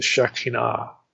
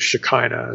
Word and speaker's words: Shekinah: The light symbolizes the Shekinah, or the Shekinah, as it's often pronounced today.